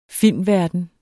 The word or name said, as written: filmverden